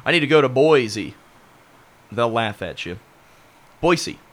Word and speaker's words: Boise: You know that's how they say it there, right? – Provo. – They don't say Boise. – Boise? – Boise.